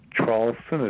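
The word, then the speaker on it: Traulsen: But my name is Hugh, H-U-G-H, a last name Charlson, is spelled T like Tom, R-A-U-L-S like Sam, E like Edward, N like Nancy.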